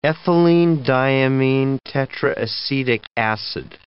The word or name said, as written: ethylenediaminetetraacetic acid